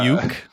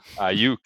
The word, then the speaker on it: Aiyuk: Brandon uh, Aiyuk. Aiyuk.